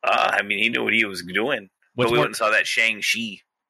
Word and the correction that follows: Shang-Chi: And it's Shang-Chi. Come on, man. Say it right.